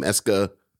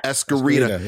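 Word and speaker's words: Esquerita: I always mispronounce his name, Esca. – Escarita.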